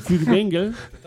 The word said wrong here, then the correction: Furtwängler: Si lo pronuncio bien. ¿Furtwängler o Furtwängel? – Furtwängler.